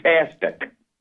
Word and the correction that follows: Tacitus: What number? – I don't even know his number. – It's number 8. It's the horse's name. Tacitus. Tacitus, yeah. – T-A-C-I-T-U-S.